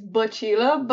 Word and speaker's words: Bacila: It's the name is Bacila, but how you say it is funnier, so you can say it like that. It's no problem.